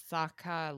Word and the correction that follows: Skaha: Lake. Anybody know? – Skaha. – Skaha. Okay. Skaha Lake.